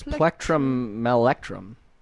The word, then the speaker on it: Plectrumelectrum: Can someone tell me how to pronounce Plectrumelectrum. – Let's go with that. Good job. – Let me try this. Plectrumelectrum.